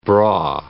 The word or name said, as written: braw